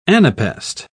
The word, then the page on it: anapaest: British English